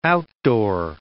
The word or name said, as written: outdoor